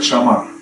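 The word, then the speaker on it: шамар (שמר): И вот это слово, которое, которое написано вот э, в бытие, слово «охранять», оно на иврите звучит как «шамар».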